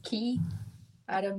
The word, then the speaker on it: Chiara: Usually I get like Chiara Meechan, and I'm like, you don't pronounce the CH as the ch, it's Chiara Meechan.